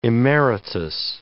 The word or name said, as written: emeritus